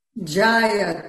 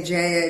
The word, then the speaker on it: Jaya: Jaya, two short A's. Jaya. Oh, yeah. Some people say Jaya, it doesn't matter.